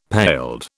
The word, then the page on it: paled: British English